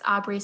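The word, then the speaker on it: Aubree: It's just my name at Aubrey Malik, and it's Aubrey spelled with two E's at the end.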